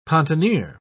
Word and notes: pontonier: British English

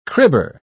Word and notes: cribber: British English

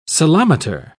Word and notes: ceilometer: British English

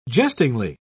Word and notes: jestingly: British English

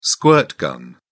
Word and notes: squirt gun: British English

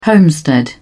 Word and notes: homestead: British English

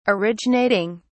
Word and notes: originating: British English

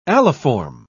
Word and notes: aliform: British English

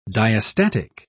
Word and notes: diastatic: British English

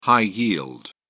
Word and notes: high-yield: British English

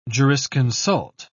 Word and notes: jurisconsult: British English

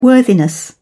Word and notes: worthiness: British English

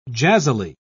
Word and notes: jazzily: British English

